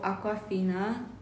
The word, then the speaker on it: Awkwafina: So, Awkwafina.